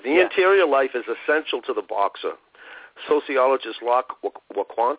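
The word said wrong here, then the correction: Loïc Wacquant: how do you say his name? – Loic Wacquant.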